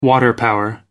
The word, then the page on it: water power: American English